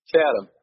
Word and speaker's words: Chatham: Is that how you say it? Chatham? – Chatham. – Chatham, yep. I'm obviously not from there.